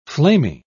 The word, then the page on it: flamy: British English